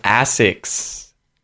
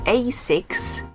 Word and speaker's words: ASICS: Ooh, so I'd pronounce this as Asics. Asics. Americans, I think, would say Asics, but I'd say Asics.